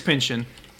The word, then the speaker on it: Pynchon: It's Pynchon. – Okay, it's just Thomas Pynchon. – Yeah, it's just Pynchon. It just sounds like a verb, you know, like, hey, I'm Pynchon.